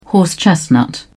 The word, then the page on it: horse chestnut: British English